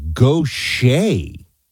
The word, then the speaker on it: Gautier: How to pronounce Gautier, it's Gautier. I'm from Mississippi, Paul says. I would not have gotten this. So that's like a Cajun, like Bobby Boucher or Bobby Gaucher. That's a weird pronunciation.